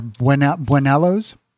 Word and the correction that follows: buñuelos: Say it right. – Buñuelos.